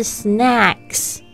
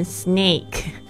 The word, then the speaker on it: snack: 好啦，那我们来一起学习点心，就是 snacks，千万不要念成 snake。 Snake 就会变成蛇的意思，它的拼法很像，但发音也截然不同，一个是 snack，一个是 snake。